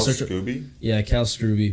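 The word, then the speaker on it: Kal Scruby: Cal Scrooby, but it's spelled Scrubby.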